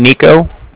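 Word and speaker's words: Niko: So it's N-I-K-O, that's my name, Nico, and then the Bank of Cardiff is spelled C-A-R. D-I-F-F, as in Frank.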